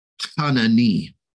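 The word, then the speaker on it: chanani (חנני): Now, I love that, because that word of favor there is the Hebrew word chanani.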